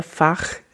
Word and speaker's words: Fach: I will say that these sub roles, so there's something called the Fach system, that's F-A-C-H, I'm trying to pronounce it in the German way, the Fach system, which has done a lot of the like categorization of these different voice types.